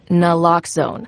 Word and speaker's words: naloxone: For the record, it's Naloxone. Nuh, like, is Naloxone addictive? Nuh. Lox, like Naloxone keeps humans safe, like locks keep bikes safe, and own, like Naloxone can save lives in our very own communities.